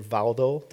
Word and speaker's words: Waldo: The first one is Peter Waldo, and then, or Valdo, that's actually how it's supposed to be pronounced, and John Wycliffe or Wycliffe.